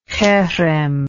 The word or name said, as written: herem